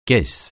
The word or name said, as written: caisse